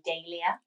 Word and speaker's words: Dahlia: So the thing is, it's two ways you can say it. So you either really look into the pronunciation of this, because I hear it different ways. In the UK, we normally would say Dahlia. – Okay. – But the botanist who it's named after, his surname is Dahl, like Roald Dahl, a Swedish botanist.